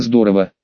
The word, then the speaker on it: здорово: Новые знания, новые умения, пусть даже в лайт-версии, это всегда здорово. Причем в здорово ударение можно что на первый, что на второй слог ставить, не ошибешься.